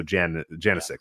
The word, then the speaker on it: Janicek: So Janicek, I believe, is how you pronounce it.